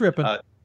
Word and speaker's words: Ripon: On a national scale, like Ripon or Ripon, I apologize on their butchering their pronunciation. – It's Ripon.